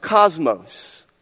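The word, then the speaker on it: cosmos (κόσμος): That word adorning comes from a Greek word that is pronounced cosmos. Spelled with a K, not with a C.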